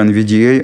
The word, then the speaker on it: NVDA: Аббревиатура NVDA обычно произносится с ударением на «э», поэтому мы перед буквой «э» напишем в данном поле редактирования пробел.